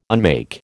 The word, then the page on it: unmake: American English